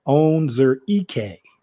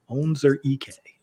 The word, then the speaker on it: Onwuzurike: I had to pull my man's name up to make sure I pronounce it right. Washington defensive tackle Levi Onwuzurike. Onwuzurike is how they had to like phonetically spell it out for me so I could make sure that I got it right against Christian Barmore.